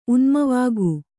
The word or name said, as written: ಉನ್ಮನವಾಗು